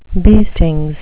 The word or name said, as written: beestings